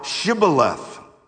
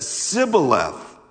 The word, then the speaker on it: Shibboleth: Then they would say to him, okay, say now Shibboleth. But if he said Sibboleth, for he could not pronounce it correctly, then they seized him and slew him at the fords of the Jordan.